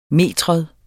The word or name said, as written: metrum